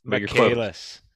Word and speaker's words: Michaelis: Michaelis.